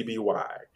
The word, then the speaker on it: Libby: If you there's an app called I don't know if it's pronounced Libby or Libby, but it's Libby.